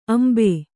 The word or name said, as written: ಅಂಬು